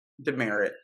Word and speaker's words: Demeritt: Demeritt, yeah.